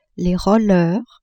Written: rollers

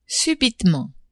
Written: subitement

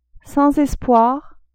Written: sans espoir

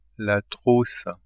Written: trousse

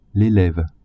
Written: eleve

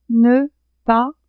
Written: ne-pas

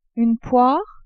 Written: poire

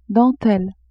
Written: dentelle

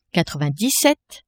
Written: quatre-vingt-dix-sept

